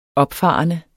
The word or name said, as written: opfarende